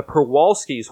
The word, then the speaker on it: Przewalski's: The Przewalski's horse, which has a Z in there, which I'm just ignoring because it makes no sense if you try and pronounce it with it.